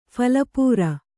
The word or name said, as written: ಫಲಪೂರ